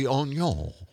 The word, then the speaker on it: oignon: Our official pronunciation expert. – It would be Oignon.